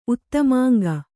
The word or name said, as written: ಉತ್ತಮಾಂಗ